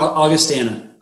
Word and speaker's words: Augustana: He played college football at August Augustana College. Am I pronouncing that correctly? – Augustana.